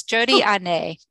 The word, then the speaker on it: Jody Hanet: So it's Jodi Ané. No, I said that wrong. – Very close. Jodi Ané.